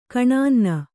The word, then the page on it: ಕೆನ್ನನೆ: kennane